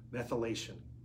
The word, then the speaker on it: methylation: The test, okay, that we talked about is based on DNA methylations levels. Methylation. I don't know why I didn't say that right, but methylation levels.